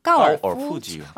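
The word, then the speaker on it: 까오어 푸지요 (高尔夫球): 까오어 푸지요. – 아, 네. 자, 여러분 여기서 퀴즈 하나 나갈게요. 네. 지금 비슷하게 했어요. 되게 네. 제가 정확한 발음 해드릴게요. 까오어 푸지요. 까오어 푸지요.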